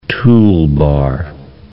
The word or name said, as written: toolbar